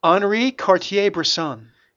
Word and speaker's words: Henri Cartier-Bresson: So, can you pronounce it, please? – Henri Cartier-Bresson.